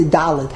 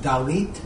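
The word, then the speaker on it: dalit (דלת): Dalid with the dal at the end? – No, no, it's always dalit with a tough at the end.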